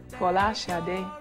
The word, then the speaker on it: Folashade: And uh, my, my name is, uh, my actual, my true Nigerian name, or the true Nigerian pronunciation, or as close as I can get to it, is Folashade, uh, which means crowning glory.